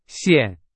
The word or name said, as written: 线